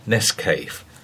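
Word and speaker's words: Nescafé: However, for the most part, it was Nescafe that dominated the market, invariably pronounced Nescafe, with an A as in hay, and none of that pretentious foreign nonsense of sounding the acute accent on the E.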